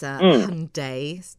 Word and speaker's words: Hyundai: I've just had a Google look at the interior of this Hyundai, to rhyme with Sunday, Staria.